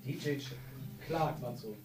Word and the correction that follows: Chark: Nee, Shark. – Nein, Shark. Shark? Von was? – Von den Jaguars. – Okay, der wird Shark ausgesprochen? Mhm. Okay. – Der ist ja CH.